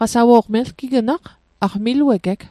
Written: qasawo'q

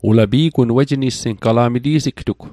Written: nissing

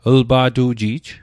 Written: lpa'tu'ji'j

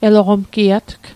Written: eloqomgiatg

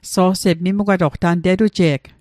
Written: mimugwatoq

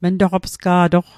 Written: mntoqopsga'toq

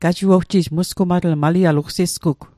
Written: musgumatl

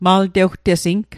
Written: maltewtesing

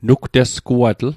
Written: nugtesguatl

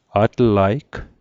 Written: atlaig

